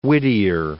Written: Whittier

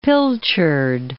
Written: pilchard

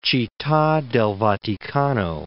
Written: Citta del Vaticano

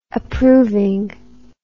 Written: approving

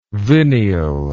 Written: vineal

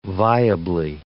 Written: viably

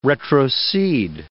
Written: retrocede

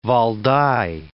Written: Valdai Hills

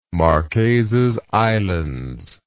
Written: Marquesas Islands

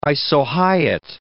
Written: isohyet

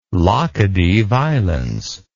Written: Laccadive Islands

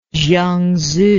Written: zhuangzi

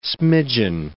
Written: smidgeon

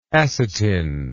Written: acetin

